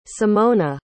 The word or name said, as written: symona